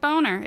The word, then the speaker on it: Boehner: His name is actually written as Boehner, B-O-E-H-N-E-R, but it's not Boehner, it's Boehner.